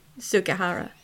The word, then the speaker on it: Sugihara: Sugihara. – Yeah. – Okay. – We should probably spell it. – It's spelled C-H-I-U-N-E, and the last name is S-U-G-I-H-A-R-A.